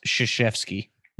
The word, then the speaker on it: Krzyzewski: I think it's pronounced Krzyzewski. – Yep. Nailed it.